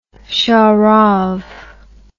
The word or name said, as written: sharav